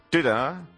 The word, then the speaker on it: Twitter: No, ¿cómo se dice? – Twitter. – Twitter. Ah, como que lo escupes, ¿no? – Twitter.